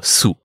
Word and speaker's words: Sioux: Genau, Sioux. Ja. Geschrieben Sioux. Ja. Wurde früher auch oft so ausgesprochen, was natürlich aber falsch ist.